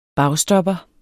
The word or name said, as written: bagstopper